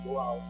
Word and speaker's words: goel: Because the Hebrew word goel, from goel, that's the way you pronounce it, means to redeem.